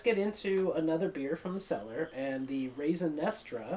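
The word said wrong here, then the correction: Raison D'Être: Actually, it's pronounced Raisin Detra.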